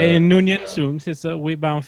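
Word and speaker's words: Khan Noonien Singh: Alors, son nom, je vais en chier pour le prononcer, c'est Khan No. Nonien Singh, c'est bon? – Ouais, je t'en ai bien sorti.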